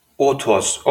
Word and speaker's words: Othoz: Und OTOS, wie schreibt man das? Äh, O-T-H-O-Z. OTOS, okay.